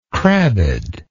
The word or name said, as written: crabbed